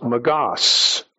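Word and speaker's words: magos (μάγος): The Greek word that's being translated here is pronounced magos.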